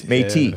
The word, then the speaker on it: Métis: He's a, how do you pronounce this? Métis. Métis? – The Métis. – The Métis. M-E with an accent over it. T-I-S. Métis.